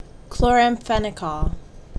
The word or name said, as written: Chloramphenicol